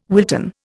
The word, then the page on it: Wilton: Indian